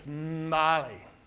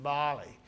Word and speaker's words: Mbale: You don't, there's no ma, it's Mbale. That's how you say it. I was corrected by a Ugandan, Mbale.